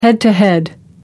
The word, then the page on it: head-to-head: American English